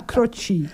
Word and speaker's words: Croce: Ja, zo spreek je het uit. Stond erbij, tussen haakjes. – Oh. – Zo spreek je het uit, Croce.